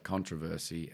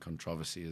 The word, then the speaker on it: controversy: Also, I like controversy and controversy as well. – Did I say it wrong? – No, it's the same word.